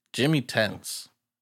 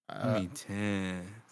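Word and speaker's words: Jimi Tense: Jimmy Tense. – Jimmy Tense. – It's spelled J-I-M-I Tense.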